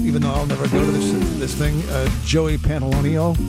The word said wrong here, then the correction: Pantoliano: Am I saying his name right? I'm not saying his name properly, am I? Joey Pants. You know this guy? Joe Pantoliano, thank you, that's it.